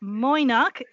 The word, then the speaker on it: Mo'ynoq: So my apologies, I mispronounced the name of this city, but Moynak is a city in Uzbekistan